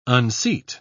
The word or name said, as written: unseat